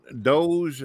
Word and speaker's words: Doge: So, Mr. Doge, is that how it's pronounced? – Yeah. Yep. Like the meme dog.